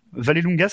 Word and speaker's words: Vallelunga: Le 14-15 juin, ça sera à un truc Vallelunga. – Vallelunga, ça, ça, se prononce comme ça, s'écrit.